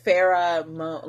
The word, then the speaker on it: Farrah Moan: And it's spelled like Farrah Moan, like Moan, like...